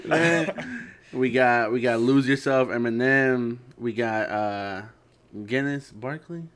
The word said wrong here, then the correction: Gnarls: Oh, Gnarls Barkley.